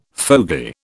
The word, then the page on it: fogey: British English